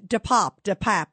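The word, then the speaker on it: DePape: Yeah, that is an interesting – and DePap is how they pronounce his name, David DePap. It sounds like frap.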